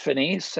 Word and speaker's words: Finis: You know, it's pronounced Finis, and Finis is kind of that old, you know, we went to a private Jesuit school and a little bit of Latin in there.